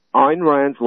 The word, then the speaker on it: Ayn Rand: But today, since Ayn Rand's life, her first name is spelled A-Y-N, last name Rand, like the Rand Corporation.